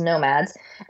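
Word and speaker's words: NomadX: It's spelled Nomad X, but it's pronounced Nomads, and they're bringing what they call Fidgetal to a plaza in Singapore.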